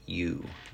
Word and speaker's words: Tsidkenu: Jehovah, Jehovah, Tsidkenu, silent T, Sidkenu, Jehovah Tsidkenu.